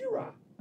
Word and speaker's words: Gojira: And it's pronounced Gojira!